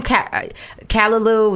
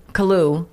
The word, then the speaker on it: Caillou: Kalalu, the little boy. – Caillou.